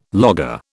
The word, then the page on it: logger: British English